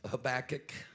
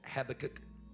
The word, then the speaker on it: Habakkuk: I want to turn your attention tonight to the book of Habakkuk. Some people call it Habakkuk. However you want to pronounce it.